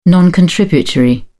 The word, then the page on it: non-contributory: British English